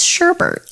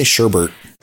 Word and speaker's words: sherbet: I know that's how it's spelled. – I don't like to say sherbet. It's sherbert. – I say sherbert.